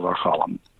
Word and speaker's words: Archallem: How do we say that? – Order of the Temple of Achalam.